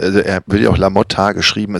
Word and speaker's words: LaMotta: Es muss, muss mit A ausgesprochen werden. Also mein, meine äh, These ist äh, hinfällig. Also Französisch Motte geschrieben Mod, ja, aber im, also im Italienischen, was sehr logisch klingt, ähm, müsste er dann noch La Motta ausgesprochen werden.